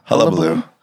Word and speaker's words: hullabaloo: and all of the hubbubaloo. How do you say that word? – Hullabaloo.